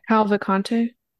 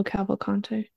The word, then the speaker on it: Cavalcante: Cavalcante. – Thank you. Say his full name now. – Danilo Cavalcante.